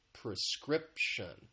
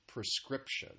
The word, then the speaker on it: prescription: One thing that I want to point out very quickly is just the pronunciation has three syllables. The stress is on that second syllable, prescription, prescription.